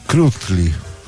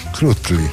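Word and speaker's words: Krüttli: Marie Krutli. – Krutli. La dieresi sopra la U, la bypassi. Non riusciremo mai a pronunciarlo come si dice.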